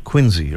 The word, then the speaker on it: Quincy: I know it's spelled Quincy, but we pronounce it Quincy around here.